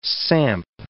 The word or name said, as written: samp